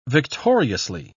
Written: victoriously

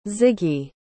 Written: ziggy

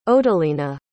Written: odelina

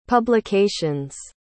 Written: publications

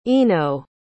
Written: eno

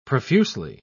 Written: profusely

